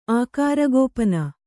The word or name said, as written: ಆಕಾರಗೋಪನ